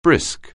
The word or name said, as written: brisk